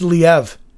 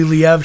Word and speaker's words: Liev: It's actually pronounced Liev. – What? – Yep, I did not know this until a couple weeks ago. He hosted SNL, mm-hmm. – and it's actually Liev Schreiber.